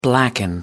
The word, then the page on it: blacken: American English